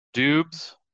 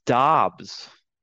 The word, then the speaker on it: Dobbs: Dubs is not the proper pronunciation, my friend. – Oh, no. Is it Daubs? – It is Dobbs. Dobbs. Okay. So the man is so shy that they called him Dubs 4 years at Nevada, and he never corrected anybody. But when asked in the combine after that, how do you pronounce his name? It is Dobbs. Kind of like D-A-H-B-S.